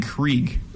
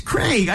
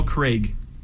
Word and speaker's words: Craig: Sounds like you're saying Krieg. – Craig? – It's Craig. I Craig. Said it. – Paul Craig. – Yeah. – Craig. Fine. I'll give you the right. I'll give you that one.